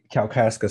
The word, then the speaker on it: Kalkaska: And so it's the Kalkaska sand. Did I say that correctly? – Yes.